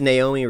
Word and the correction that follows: Noomi: Rapace. Maybe. – It's not Naomi. This Noomi. It's definitely.